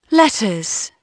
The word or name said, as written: letters